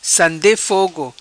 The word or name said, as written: Sande Fogo